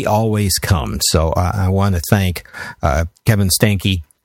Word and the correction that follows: Steinke: Did I say it right? – The Steinke? – Steinke. Okay. – It's, yeah. It's a beer stein and a house key.